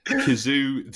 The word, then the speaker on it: Cazoo: Well, the thing is, it's spelt with, so this is spelled K-A-Z-O-O. The direct translation is C-A-Z-Z-O. So it's like Cazoo, So if you said it out loud, it'd be like Cazoo. – Same pronunciation.